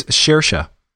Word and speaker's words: Saoirse: Uh, not not with uh, I don't remember how to say her name, Sirois Ronan. Oh, it's Shersha.